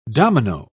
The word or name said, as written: domino